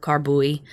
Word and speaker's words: carboy: And then he bought a stirrup pump to get the acid from those, well, some stuff says it's pronounced carboy. Some stuff says it's called carbuy.